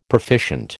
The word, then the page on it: proficient: American English